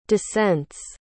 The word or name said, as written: dissents